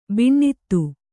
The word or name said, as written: ಬಿಣ್ಣಿತು